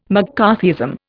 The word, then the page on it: McCarthyism: Indian